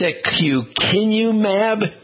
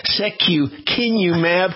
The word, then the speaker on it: secukinumab: It's secukinumab, and this is the scientific name.